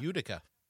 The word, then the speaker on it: Utica: Am I saying that right? Utica? – Utica. – Utica? Yeah.